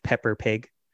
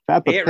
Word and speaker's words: Peppa Pig: And she'd be like, yes, I want to watch Pepper Pig. – I would enjoy we were saying it with a Boston accent. – Peppa Pig kid. – Say it right, it's Peppa. – Peppa Pig.